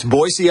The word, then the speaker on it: Boise: People always say it with a Z, right? – That's right. It's Boise, Idaho.